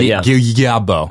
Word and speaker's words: Guillebeau: as I think it's pronounced Guillebeau, but Guillebeau. – Yeah.